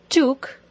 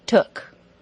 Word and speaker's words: took: Now don't say that word with an OO pronunciation like took. It should be took.